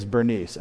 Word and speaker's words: Berenice: And that girl's name is Bernice, and that is how you spell that universally, everywhere I've seen.